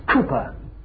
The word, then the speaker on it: Cowper: And the friend was William Cowper, written William Cowper, C-O-W-P-E-R, but pronounced in those days Cooper.